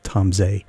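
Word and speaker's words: Tom Zé: Is the best I can do with Portuguese, but Tom Zay is correctly pronounced, and you need to listen to Tom Zay as much as you can.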